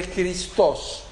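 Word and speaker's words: Christos (Χριστός): Cristo es el griego que se pronuncia aproximadamente Christos.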